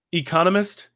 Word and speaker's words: economist: That word is pronounced economist. – Economist. I can't remember where the accent on that one goes. – I think you put the accent on the wrong, on the wrong syllable.